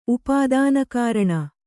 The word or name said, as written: ಉಪಾದಾನಕಾರಣ